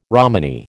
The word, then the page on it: Romany: American English